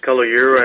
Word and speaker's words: Kalogirou: Well, my full name is Vasileios Kalogirou,